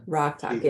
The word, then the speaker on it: Rok-Tahk: Rock, Rok-Tahk. talk. yeah. – Rok-Tahk, yes.